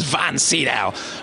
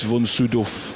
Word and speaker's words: von Sydow: And I once met him, and I said, how do you pronounce your name? And he said, well, how do you pronounce it? And I said, well, von Sydow. He said, well, officially, it's von Sydow.